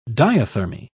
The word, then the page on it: diathermy: British English